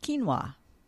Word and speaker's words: quinoa: Okay, the next one is another word that I needed to learn to pronounce, and it's spelt Q-U-I-N-O-A. And I'm thinking a lot of you have seen that because it's really being advertised out there, and it's a cereal, a grain, and it's pronounced quinoa. Q-U-I-N-O-A is pronounced quinoa.